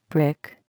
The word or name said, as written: brick